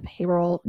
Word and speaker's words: Haverhill: The way it's spelt is not the way it's said. – English is hard. – Well, listen, it's spelt Haverhill, okay? Haverhill, okay. New Hampshire, but they call it Haverhill.